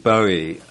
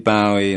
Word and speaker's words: Bowie: I think the correct pronunciation is David Bowie.